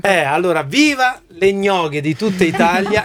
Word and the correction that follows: yoga: Le yoga, scusa, ho sbagliato. Andiamo avanti con la musica, scusate, ho sbagliato io la pronuncia.